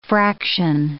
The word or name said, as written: fraction